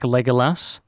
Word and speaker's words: Legolas: It'd be a tough call between an elf like Legolas or... – That's not the Australian way to pronounce his name, by the way. – What is it? – Legolas. – Legolas. Fucking Legolas, mate.